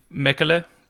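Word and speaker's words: Mäkelä: Karimati Mekele. It's like a umlaut over the A's. It's Finnish, I think.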